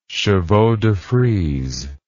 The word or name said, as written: chevaux de frise